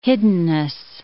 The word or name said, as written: hiddenness